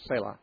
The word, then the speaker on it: Selah: That's just the way it's always been pronounced by every person I've ever heard read it. I believe it's Selah.